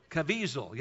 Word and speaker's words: Caviezel: How do you pronounce that? Caviezel. Yeah, Jim Caviezel.